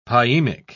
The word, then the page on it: pyemic: British English